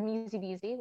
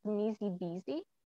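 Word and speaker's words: Msi-bissi: I think it's pronounced Musy Beezy. – Okay. – It's spelled MSIBISSI.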